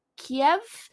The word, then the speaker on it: Kiev: And also, when I refer to the entity that is the Kievan Rus, I'm going to say Kiev instead of Kyiv. You pronounce the city Kyiv, but when you're pronouncing the entity Kievan Rus, you say Kiev instead of Kyiv.